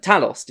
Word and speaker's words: Talos: That, Talos, did you say his name is? – Talos, Ben Mendelsohn's character.